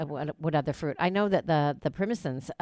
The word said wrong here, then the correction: persimmons: How do you say it? – persimmons, right? Persimmons. Persimmons.